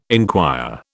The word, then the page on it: inquire: British English